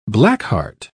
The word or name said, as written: blackheart